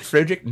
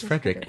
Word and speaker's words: Frederick: This is, do you also say Frederick? – Eigel. – Yeah, is it Frederick? No, it's Frederick. Well, why isn't it Frederick Frankenstein? It isn't. It's Frederick Frankenstein.